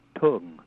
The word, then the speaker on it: tung: He wrote four choral arts ensemble, and he has some syllables, a ting and a tung. T-U-N-G, it's ambiguous about what vowel did he really mean there, but he wants a uh. – Okay. – Not a ooh or a but a tung.